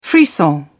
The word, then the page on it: frisson: British English